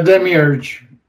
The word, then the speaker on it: Demiurge: But he was talking about the Demiurge. Did I say that right? – Yes. The Demiurge.